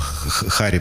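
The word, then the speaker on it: Harry: Человек, которого можно, если правильно произносить его имя, то можно немножечко почувствовать себя украинцем. Потому что это Харри Белафонте.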